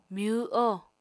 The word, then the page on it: myw-oho: Apatani